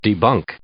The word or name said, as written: debunk